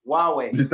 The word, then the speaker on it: Huawei: Huawei. – That's why. – they got. It's a Chinese brand, isn't it? – Oh, how do you spell that? – H-U-W. It's like Hawaii.